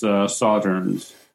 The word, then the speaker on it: Sauternes: Pretty close. You know, I'm no expert, but I'm pretty sure it's Sauternes. – Yeah. Sauternes casks. I've never had any whiskey in Sauternes casks.